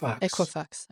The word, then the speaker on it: Equifax: Equifax. – Equifax, okay.